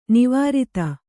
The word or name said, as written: ನಿವೃತ್ತ